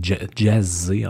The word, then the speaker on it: Jasay: Donc, euh, Anthony de jay ou de jay je pense que c'est Jazzy, en fait, que ça se prononce techniquement, est né le 15 octobre 1925 à Haba, donc en Hongrie, et il est mort le 23 janvier 2019, donc tout, récemment.